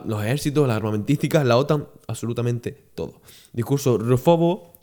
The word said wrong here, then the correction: rusófobo: rufo, rusófobo, perdón, me da que lo dije mal antes.